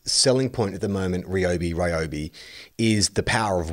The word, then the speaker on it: Ryobi: Ryobi is how it's pronounced. – Uh, Podcast okay. Mike has put that uh, up on the little message uh, window. And it says, it's Ryobi, source, I can read Japanese.